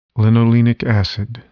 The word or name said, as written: linolenic acid